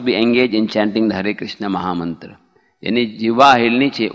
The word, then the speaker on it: हरे कृष्ण: द word हरे कृष्ण should बी वेरी डिस्टिंक्टली प्रोनाउंस एंड हर्ड, स्पष्ट, जैसे हरे कृष्ण ऐसे नहीं कि प्रभुपाद जी बोलते do not make a hissing sound, हरे कृष्ण, do not make a hissing sound, instead of चैंटिंग with proper pronunciation with द help of द lips and द tongue, होठ भी हिलने चाहिए।